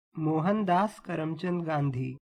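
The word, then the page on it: Mohandas Karamchand Gandhi: Hindi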